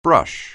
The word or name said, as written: brush